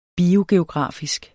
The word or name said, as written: biogeografisk